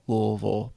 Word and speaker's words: Louisville: That's the proper way to say it. Louisville, by the way.